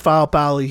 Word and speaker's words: Faopali: She told me that's how you pronounce his last name. So I'm going with Faopali here, you know, but excuse me if I'm saying that wrong.